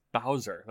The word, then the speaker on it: dowser: I'm going to say dozer. – That wrong every single time. – Dozer. Dozer. – But it's Bowser. That's what's throwing me off. It's pronounced Bowser.